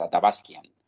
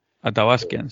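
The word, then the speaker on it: Athabaskan: Era, eh, si no recuerdo mal, eh, no estoy seguro cómo se pronuncia en español, pero en inglés es Atabaskian. – Atabaskian, sí.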